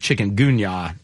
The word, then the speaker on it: chikungunya: I chewed alive in the backyard yesterday, but those aren't from the chikungunya mosquitoes.